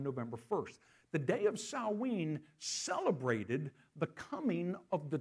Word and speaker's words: Samhain: It's spelled S-A-M-H-A-I-N, Samhain, but it's pronounced Samhain. Catch the last syllable of that, een?